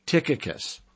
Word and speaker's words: Tychicus: How about Ephesians 6:21, Tychicus? That's how you pronounce that, Ephesians 6:21, Tychicus,